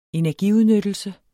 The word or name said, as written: energiudnyttelse